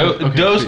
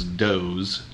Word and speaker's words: Dohse: My dad came home and said, "Oh, I've found out that it's Dohse, like a Z sound. It's D-O-H-S-E." And he was like, "It's actually pronounced Dohse, not Dohse."